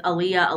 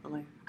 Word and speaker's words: Aaliyah: You can tell he trusts her because he asks her about this gossip and drama with Aaliyah. That's how you say it. – Aaliyah.